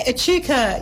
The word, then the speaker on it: Echuca: End of this month on the 28th, you're at Echuca. Is that how you say it? – Yeah, Echuca. Yeah, on the river there.